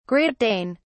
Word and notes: Great Dane: British English

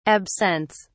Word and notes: absents: British English

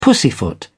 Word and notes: pussyfoot: British English

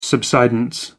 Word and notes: subsidence: American English

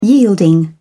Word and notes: yielding: English; British English

